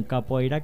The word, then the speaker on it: Capoeira: Tenemos invitado al día de hoy al joven Ítalo Angulo, el cual tiene un grupo llamado Unión Capoeira Caracas, o Unión Capoeira Caracas, para pronunciarlo un poquito mejor en portugués,